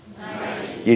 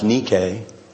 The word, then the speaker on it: Nike: Nike. – You just spoke Greek. Kinda. We've Americanized it. It's really pronounced Nike. But we've Americanized Adidas too. Adidas comes out of Germany. They pronounce it Adidas.